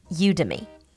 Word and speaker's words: Udemy: Number two on the list is Udemy. It is spelled U D E M Y. I think this is a combination of U and Academy.